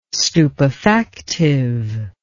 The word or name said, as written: stupefactive